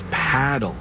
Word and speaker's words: Padel: No, that's paddle. Rhymes with saddle.